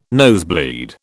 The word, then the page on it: nosebleed: British English